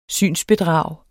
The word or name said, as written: synsbedrag